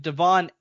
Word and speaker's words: Devon: No, apparently they're calling him Devon Achane. – Oh, now. For fuck's sake. I even got the first name wrong. – Yeah, Devon Achane. I believe that's how you pronounce it now. Achane, but yeah, for the longest time, it was Devin Achane. That is Devin Achane. Now it's Devon Achane.